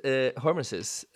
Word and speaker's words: hormesis: So, you just mentioned hormesis, and I heard you talking about that.